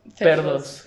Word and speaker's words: Perdos: Perdos. Como pedos, pero con, con R.